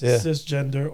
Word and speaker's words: cisgender: So it's S-I-C? – No, C-I-S. Right, cis male. – C-I-S male. It's going to be interesting. Cis, short for cisgender, pronounced cisgender or just cis, is a term that means whatever gender you are now and the same as what you were presumed for your birth.